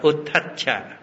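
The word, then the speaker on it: uddhacca: Tiếng Bali là Uttaccha, đọc là Uttaccha.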